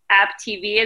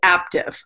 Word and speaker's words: Aaptiv: AppTV, is that how, am I saying it right? – It's Aptive. I know that's confusing. – Oh, like active, yes.